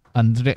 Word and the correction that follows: Andrzej: Andrzej Sapkowski, es como se pronuncia, perdón.